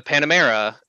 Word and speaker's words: Panamera: And I helped them roll in the Panamera, if I'm saying that right.